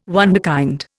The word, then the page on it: wunderkind: Indian